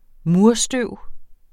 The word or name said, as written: murstøv